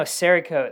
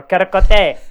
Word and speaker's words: Cerakote: Oh, no, Cerakote, that's how you know. Or Caracote, for those who can't pronounce things good.